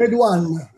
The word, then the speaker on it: Redouan: moderato islamico Abdellah Redo, mm, la pronuncia mi è un po' difficile. – Redouan. – Redo, mm, come si chiama? Redouan